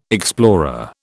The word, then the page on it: explorer: British English